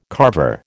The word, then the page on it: Carver: American English